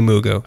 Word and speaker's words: Mugu: It must be Mugu. It's not Mr. Magoo. Which came first? Let's have that. Let's have Bruno correct us. Point Mugu. – Like Magoo. – Magoo. M-U-G-U.